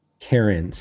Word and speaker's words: Cairn: Cairns, C-A-I-R-N-S. This is a, you know, kind of New York, North American pronunciation. It, you know, that word comes from, I believe, Ireland. So I'm probably saying it without the right accent, but I say Cairn.